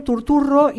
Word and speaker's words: Turturro: Hay un gran actor, es un actor que yo quiero mucho y que actúa muy poco, que es John Turturro, y se pronuncia así porque es de origen italiano, se dedicó más a la dirección y demás.